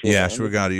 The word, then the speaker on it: ashwagandha: But I was just wondering: is there any kind of restrictions on ashwagandha? Is that how you say it? – Ashwagandha. You're saying it correctly.